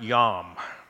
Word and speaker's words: yom: Well, there's a Hebrew word that's translated as the word day, and it's pronounced yom.